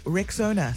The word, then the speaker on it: Rexona: It's pronounced Rexona.